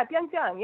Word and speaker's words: Pyeongchang: And what you lack in 500-pound gorilla idioms, you make up for by I'm going to botch this probably, but you at least know how to pronounce Pyeongchang. Did I get that right? – That was close. Yeah, Pyeongchang.